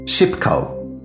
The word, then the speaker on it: Szybko: Szybko. Ooh, that's awfully difficult to pronounce. Try it. – Oczywiście. Chodźmy. Szybko.